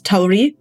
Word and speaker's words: Tawree: So I pronounce it a little bit differently. I pronounce Tawree.